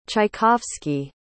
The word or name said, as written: tchaikovsky